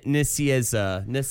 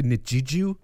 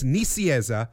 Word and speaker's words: Nicieza: At some point, it devolved to Nijiju, but it's Nicieza.